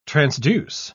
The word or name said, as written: transduce